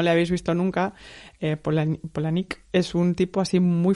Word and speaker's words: Palahniuk: Nos pusieron en una entrevista conjunta con más gente todos a competir por hacerle pues preguntas a Chuck Palahniuk, que de hecho se pronuncia Polanik, pero bueno.